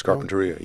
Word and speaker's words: Carpinteria: Which is where we've reached NPR's Eric Westervelt, who is near the coastal community of Carpinteria. Am I saying that correctly, Eric? – You're pretty close, Carpinteria, well, yeah.